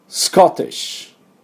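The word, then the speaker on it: Scottish: スクスクスコスコ、ここまでいかないとスコティッシュっていうと綺麗な音になりますね。ダブルシーンです。一発で言いましょうということです。ではちょっと発音いきましょうか。スコティッシュ、スコティッシュ、はい、でこのスコットランドの人たちのことをスコティッシュというんですが、意外と日本人分かってないのですね。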